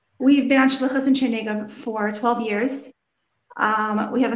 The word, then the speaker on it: Chernigov: Chernigov.